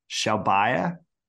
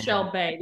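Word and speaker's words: Shelbayh: So Laura picked Carlos Alcaraz, top 10, Hurkacz in field, Tsitsipas in Netflix, Shelbaya, is that how you'd say it? – It's Shelbey. The only reason I know that is because I did listen to how he pronounced it on the ATP website.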